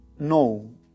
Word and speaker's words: know: Entonces yo le preguntaba, oye, ¿por qué está la K antes de esta? ¿Cómo se debe pronunciar? Porque hay una K. Entonces él me decía, se pronuncia, tienes que hacer un ruido, no, no. Entonces, porque hay otra palabra que es now, okay? N-O-W, esta es K-N-O-W.